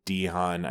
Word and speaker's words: DeHaan: And Dane DeHaan, I think is how you pronounce this last name.